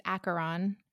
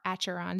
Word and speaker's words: Acheron: Demon Archeron, is that how you say his name? Archeron. – There's no Archeron. It's Acheron or Acheron. There's no R.